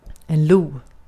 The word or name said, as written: vaşak